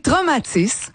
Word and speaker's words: Traumatis: On va tout de suite parler de l'auteur David Goudreau qui va sortir son nouveau roman qui s'appelle Traumatis, c'est comme ça qu'il faut que je le prononce? – Oui, Traumatis.